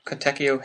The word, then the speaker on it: Katekyo: Kateko or Kateko? Katekyo Hitman Reborn manga that I've ever actually seen. – KYO. – Yeah. – KYO.